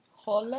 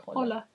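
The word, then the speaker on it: Höller: Carsten Holler. – Holler. How do you say the O with the little I think just dots like Holler. Holler.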